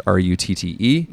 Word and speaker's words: Rutte: And again, because the pronunciation is wacky, it's Rutte.